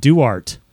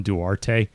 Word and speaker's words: Duarte: Hey, he said pronounced it Duart. Yeah, because I keep pronouncing it wrong. I keep calling him Duarte because I'm stupid and uncultured.